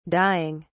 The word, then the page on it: dieing: British English